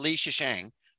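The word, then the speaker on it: Lei Zhicheng: Is that it? – That sounds right. – Yeah, Yang Wening and Lei Shisheng. Which is also how, I mean, I looked it up online on how to say it, and that's how they said it as well.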